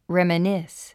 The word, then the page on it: reminisce: rèmənís; レメニス